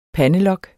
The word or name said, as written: pandelok